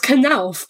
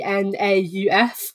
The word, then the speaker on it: Knauf: I asked about it's it's Knauf, but it's spelled K N A U F. Um, so usually in English you'd be like Nauf or North or you know.